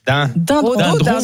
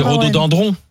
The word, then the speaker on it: rhododendron: Dindron. – C'est piégeant. – C'est dendron. Alors c'est rhododendron. – Ah bon, oui. – Est-ce qu'on y a... Ça s'écrit dendron.